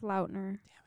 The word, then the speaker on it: Lautner: No, you mean Taylor Lautner? – It's Lautner. – Damn it. I didn't know which one was the correct pronunciation.